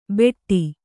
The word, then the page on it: ಭೆಟ್ಟಿ: Kannada